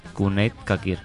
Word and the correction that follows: Çakır: No, pero la cesa no se pronuncia así. – Es ¿Ah, no? Çakır, ¿no? – Çakır. – Çakır. Sí.